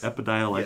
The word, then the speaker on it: Epidiolex: Epidiolex.